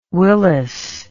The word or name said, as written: willless